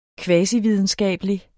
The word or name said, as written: kvasividenskabelig